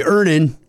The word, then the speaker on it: Earnin: I'm going to pronounce this properly. Earnin. E-A-R-N-I-N is pronounced Earnin.